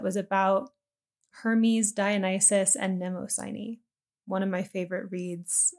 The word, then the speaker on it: Mnemosyne: So about memory, first I want to share just like a broad kind of mythic perspective, because there's this goddess Mnemosyne, and that's spelled, Let me look it up again. It is M-N-E-M-O-S-Y-N-E, Mnemosyne.